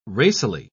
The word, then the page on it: racily: British English